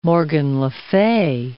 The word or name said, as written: Morgan le Fay